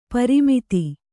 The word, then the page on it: ಪ್ರಮದೆ: Kannada